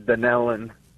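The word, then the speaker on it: Dunnellon: I'm going to go with Dunalon, Florida, watching on YouTube. I hope I said the town right. I have not heard of that town before. – No, actually it's pronounced Dunellen.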